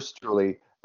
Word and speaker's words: Streule: So is Streule your preferred last name pronunciation? – Yeah. I do prefer Streule.